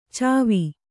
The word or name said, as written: ಛವಿ